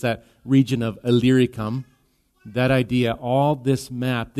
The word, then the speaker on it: Illyricum: Illyricum.